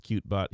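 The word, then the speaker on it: Kyootbot: Nope. Pronounced Cutebot. He wrote it right there. Spelled K-Y-O-O-T bot.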